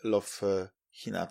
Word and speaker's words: Love Hina: mangę Love Hina, tak się wymawia.